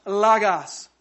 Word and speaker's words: logos: In the Greek, it's pronounced logos or logos.